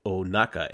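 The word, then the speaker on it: Ohnaka: Sorry, I butchered that. Hondo Onaka.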